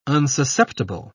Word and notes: unsusceptible: British English

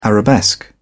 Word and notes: arabesque: British English

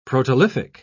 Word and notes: protolithic: British English

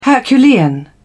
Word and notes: herculean: British English

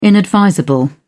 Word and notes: inadvisable: British English

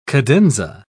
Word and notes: cadenza: British English

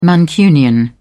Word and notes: mancunian: British English

